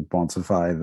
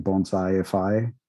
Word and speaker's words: Bonsify: I've gotten so many crazy pronunciations of Bonsify that I so a lot of people, like, they end up saying Bonsai-ify, like they're putting an extra I in there. – Yep. – And then I think Bonsafi,